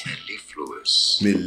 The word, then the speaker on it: mellifluous: Mellifluous. – So I was wrong. It's mellifluous.